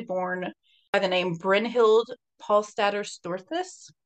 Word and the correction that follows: Storseth: Storseth? Didn't say that right. – I'll try it again. Belle was actually born by the name Brynhild Paulsdatter Storseth on November 22nd, 1859, in Selbu, Norway.